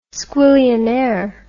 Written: squillionaire

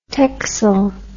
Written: texel

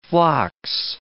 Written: phlox

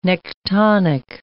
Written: nektonic

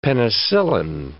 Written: penicillin

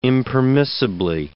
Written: impermissibly

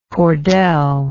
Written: cordelle

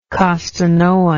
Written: costanoan

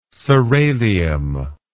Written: soralium

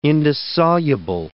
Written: indissoluble

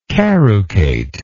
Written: carucated

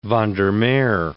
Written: van der Meer

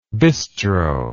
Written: bistroic

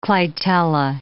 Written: clitella

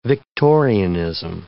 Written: Victorianism

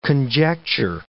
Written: conjecture

